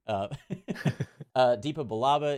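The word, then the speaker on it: Depa Billaba: And we see how his master, Deepa Balaba, pronunciation check, is that? – Yes, correct. – Cool. Deepa Balaba is killed in action by her own clones.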